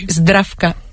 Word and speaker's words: Zdravka: Ayúdanos a pronunciar los autores. – A ver, Georgi Gospodinov, Zdravka Eftimova y Vera Mutavcheva. – Ahora sí, ahora sí.